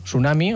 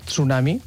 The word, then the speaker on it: tsunami: En tsunami tenim dues opcions. Podem fer tsunami o tsunami. Jo crec que és més còmode sense fer la T inicial.